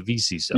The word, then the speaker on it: VCISO: Am I even saying it right? VCISO? That's how I would say it, the VCISO.